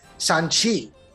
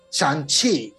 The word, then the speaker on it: Shang-Chi: Na verdade, tipo, a, a Disney deixou claro que a gente tem que aprender como se fala Shang-Chi. No filme ele fala Shang-Chi, Shang-Chi.